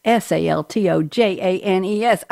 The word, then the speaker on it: Saltojanes: So Keith Saltojanes, Saltojanes. I said it right.